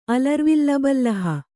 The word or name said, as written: ಅಲರ್ವಿಲ್ಲಬಲ್ಲಹ